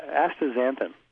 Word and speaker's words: astaxanthin: Yeah, astaxanthin. and um, so the astaxanthin, uh, I mentioned the one study where you saw diminished facial lines when patients took astaxanthin for a period of 12 weeks.